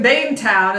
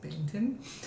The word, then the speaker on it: Bainton: And Bane, who is my favorite villain, makes his Bainton, but it's spelled like Bainetown. And so people are always like, we're here in Bainetown. And like, it's actually pronounced Bainton.